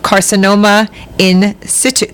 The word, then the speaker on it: carcinoma in situ: There are about 63,000 new cases of carcinoma in situ.